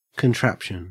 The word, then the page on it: contraption: Australian English